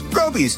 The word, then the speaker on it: Groby's: Groby's, where the accent is on grow.